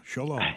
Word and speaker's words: shalom: Yeah, shalom.